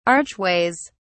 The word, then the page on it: archways: British English